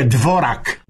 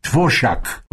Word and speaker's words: Dvořák: Eu tinha um tal de Dvořák, compositor, que a turma escrevia Dvorak, né? Sim. E era pra gente saber se pronunciava certo. Se não me engano, a pronúncia era Dvořák.